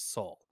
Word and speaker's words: Sol: System, not uh, it, it, no it, it, helps if you actually uh, pronounce it as Sol, like Sol. Better call Saul.